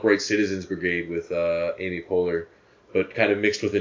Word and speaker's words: Poehler: Yeah, you guys would say Poehler. Yeah, but her, she pronounces it Poehler.